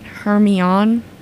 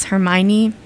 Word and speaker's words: Hermione: I pronounced it Hermione. Her name was Hermione.